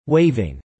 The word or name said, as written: waiving